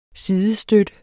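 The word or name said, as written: sidestøtte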